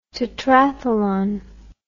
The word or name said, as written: tetrathlon